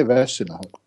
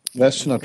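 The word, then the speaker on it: Vezina: Men okej, okay, då tar vi Vessina då. Eller Vesina, eller hur du vill uttala det. De säger Vessina Ja, okej, okay, då säger, då säger vi så. Vessina Trophy.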